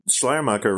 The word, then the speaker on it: Schleiermacher: Although Schleiermacher, right? And is that how you pronounce it? – Yes, it is.